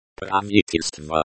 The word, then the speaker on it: Правительство: Правительство. Pra-Roman 6-tel, STVO, this is the noun, government. The stress is on the third syllable.